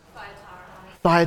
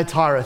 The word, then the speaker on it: Thyatira: Thyatira. – Thyatira, thank you.